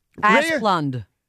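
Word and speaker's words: Asplundh: Asplund.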